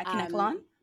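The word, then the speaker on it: Kanekalon: So like, box braids are when they like take the fake hair, like the caniculon hair, I guess, if I'm saying that right. – Yeah, caniculon.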